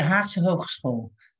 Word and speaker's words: Haagse Hogeschool: I cannot pronounce the Dutch word. May you please say it for me? – it's the Haagse Hogeschool.